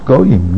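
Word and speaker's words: Goyim (גוים): Excuse me, it's pronounced Goyim, nations, but it's written Geim.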